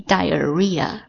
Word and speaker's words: diarrhea: 然后痢疾呢叫做 diarrhea， d-i-a-r-r-h-e-a，这个 h 的话是废物，在这个地方它不发音 diarrhea，痢疾或者腹泻。